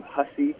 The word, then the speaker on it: Hussey: Yeah, so my name, Sean, is the S-E-A-N version, and then my last name, Hussey, H-U-S-S-E-Y.